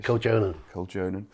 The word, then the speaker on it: Koljonen: Fintopia, you co-wrote it with Annika Koljonen. Yeah. Is that the right pronunciation? – Koljonen.